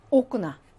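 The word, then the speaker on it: 오크나 (окна): 마일린키 아크나. 오크나. 오크나죠. 강세가 오에 있습니다. 그래서 오 발음 나고요.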